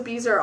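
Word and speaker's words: bezoar: Okay, it said, "You thought of a Beezer all by yourself, did you?" – I thought it was Bizarre. – Bizarre? – I thought it was Bizarre. – It's B-E-Z-O-A-R.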